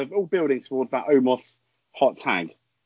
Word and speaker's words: Omos: Amos. I never know how to pronounce his name. – Oh, Amos, the Raw tag team match. – Omos. – Xavier Woods. Omos.